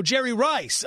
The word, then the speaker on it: Jerry Rice: We're pronouncing this Gerard Riche? – Something like that. – Oh, Jerry Rice. Oh, that's how you say it.